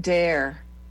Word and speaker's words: Doerr: it's pronounced Dare.